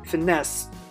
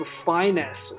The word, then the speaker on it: finesse: 那这个词呢，一看就知道是法语词，所以说你这个读音要注意，是读的是这个 finesse，对吧？它这过去是 finessed，你不要读成这个 finesse or anything else。